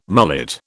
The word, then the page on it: mullet: British English